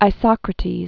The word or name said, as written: Isocrates